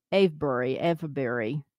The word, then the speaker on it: Avebury: A-V-E-B-U-R-Y.